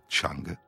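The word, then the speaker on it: Chang (鬯): Un vin aromatisé aux herbes, désigné par le caractère qui se prononce aujourd'hui Chang,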